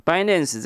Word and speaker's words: Binance: 如果千万粉丝有接触币圈的话，一定听过一个交易所叫做币安（Binance）哦。 Binance 这个其实就是 finance 的 F 换成这个比特币的 B 啦哈，就是叫 Binance。